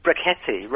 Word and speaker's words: Brachetti: This is Arturo Brachetti. Rhymes with spaghetti.